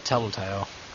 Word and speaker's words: Telltale: Telltale? Am I saying that right? – Yeah, Telltale.